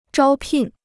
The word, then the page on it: 招聘: zhāo pìn